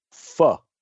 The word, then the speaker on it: pho: So I have mentioned before on the show, and I'm going to go to you, to give me the proper pronunciation, pho. – Pho. – Okay. Pho. Yeah, it's not pho, it's pho. That's why right.